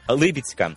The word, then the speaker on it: Либідська: Правильно каже диктор Либідська. Тому що є іменникове наслідування наголосу від іменника похідного Либідь, річка, Либідський і площа Либідська, а не Либідська.